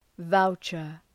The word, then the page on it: voucher: ‘vaʋtʃər